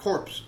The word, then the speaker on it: Corps: You don't pronounce the P. – Hey. I'll pee when I want to pee. – Okay, go ahead and pee. – All right, it's Corp. Yep, we knew this movie was coming.